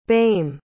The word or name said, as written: boehme